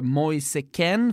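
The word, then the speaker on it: Kean: Moise Keane. Ja. Som i Italien blev Moise Ken för att italienarna uttalar som de gör.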